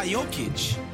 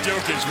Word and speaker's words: Jokic: Or it could be Nikola Jokic. – Wow. I mean, you said his name right, so it must have. Now it is. We're sweeping it. Jokic.